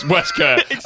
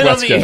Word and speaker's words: waistcoat: And then got it's actually pronounced Wesker. And all he's done is he's done some really authoritative clicking and then not showing me anything. And just gone, it's pronounced Wesker.